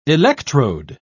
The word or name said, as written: electrode